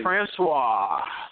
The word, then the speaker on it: Francois: Is it, how do you pronounce his name, Francois? – DeAndre Francois.